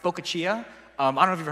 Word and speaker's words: focaccia: Unfortunately for me, in Korean, this is pronounced pokachia.